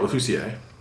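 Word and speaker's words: Lofficier: How did you say it earlier, Lothisier? – I've said Jean-Marc Lefoussier. – Lefoussier, okay, Lefoussier, that makes sense.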